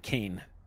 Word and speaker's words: Cayne: The guy that handed the stick of gum, his name is Private Kane. But it's spelled differently. It's spelled C-A-Y-N-E.